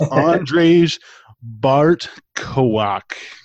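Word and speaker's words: Andrzej Bartkowiak: And you know, I understand when a movie does it for jump scares, and the director of this movie, Andrzej Bartow, I had trouble pronouncing this earlier. It's Andrzej Bartkowak. Forgive me for butchering that horribly. – But, I think you nailed it.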